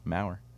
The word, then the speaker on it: Mauer: Rhymes with power. Mauer. Darrell. Two syllables.